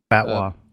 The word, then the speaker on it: fatwa: The General Authority of Islamic Affairs and Endowment in the UAE has issued a fatwa, or I'm probably saying that wrong. – Fatwa. – Fatwa, thank you.